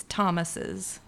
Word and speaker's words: Thomases: Well, I've just found it's easier than trying to get people to pronounce my last name, which is Thomas's. That tends to trip people up. – Because they probably spell it T-H-O-M, right? – Yeah, it sounds like plural Thomases.